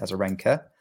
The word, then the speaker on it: Azarenka: She gritted her way through two deciding set tie breaks, including saving match points against Azarenka. Got the name right this time.